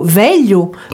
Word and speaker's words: velho: C'est un peu comme un L mouillé on, dit, par exemple, dans le mot veglio, veglio qui veut dire vieux. C'est un son assez difficile à prononcer, je l'avoue, puisque euh, on doit le prononcer, ces deux lettres, comme si on avait les deux L, deux meilleurs, mais en mettant la langue à plat contre le palais.